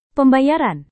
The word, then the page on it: pembayaran: Indonesian